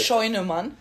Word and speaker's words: Scheunemann: Scheunemann.